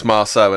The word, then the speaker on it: Marceau: Is it Sophie Marceau? Marceau. – Marceau. – For someone who speaks frog, please pronounce that properly. – It's Marceau, isn't it? – Thank you.